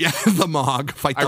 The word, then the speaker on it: Mogg: Is Mogg, is that how you say his name? – That's how I've said it.